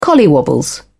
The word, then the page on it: collywobbles: British English